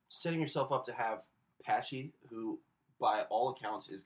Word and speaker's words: Pache: We got to figure out how to pronounce this guy's name. – It's Patchy. – Patchy? – Patchy. – I'm going to say Pache.